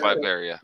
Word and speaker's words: Latveria: I just want to say, you know, I, not being a avid Marvel reader, learning that Latvia adores Doom. – Oh yeah. – I thought it's Latveria. Is it Latveria? – Yeah, it's Latveria.